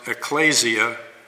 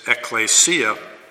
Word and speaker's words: ecclesia: You'll sometimes hear people pronounce it ecclesia, and that's incorrect. It is ecclesia.